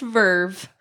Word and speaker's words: VRV: I would be a bad Elation employee if I didn't say that it is actually pronounced Verve.